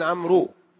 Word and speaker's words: عمرو: عمر بغير واو، فإذا صارت عمر بالواو هل نقول أن عبد الله بن عمرو؟ لا، هذه تكتب ولا إيش ولا تنطق.